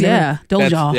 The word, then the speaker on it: Dolezal: Hot wings and naked wings with Rachel Dolezal. Is that how you say it? – Hot wings, Dolezal.